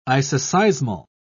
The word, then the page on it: isoseismal: British English